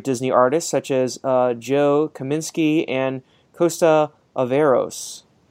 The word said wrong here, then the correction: Alaverzos: Alaverzos. There we go.